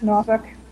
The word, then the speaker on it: Norfolk: Is it Norfolk? Is that how the natives pronounce it? – That's Norfolk, yeah.